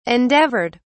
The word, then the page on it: endeavored: British English